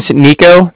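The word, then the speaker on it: Niko: My name's Nico. That's N-I-K-O. – Uh-huh. – And then I'm calling from Cardiff. That's spelled C-A-R-D-I-F-F, as in Frank, Frank.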